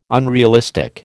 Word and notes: unrealistic: American English

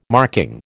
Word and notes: marking: American English

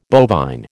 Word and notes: bovine: American English

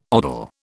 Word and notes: odal: British English

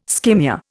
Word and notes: skimmia: Indian

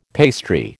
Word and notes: pastry: American English

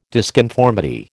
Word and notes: disconformity: American English

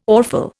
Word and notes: awful: Indian

